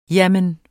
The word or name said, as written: jamen